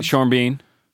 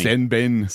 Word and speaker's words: Sean Bean: Sean Bean. – Interesting. – What have you been saying? – Sen Ben.